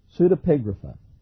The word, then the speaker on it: Pseudepigrapha: Pseudepigrapha.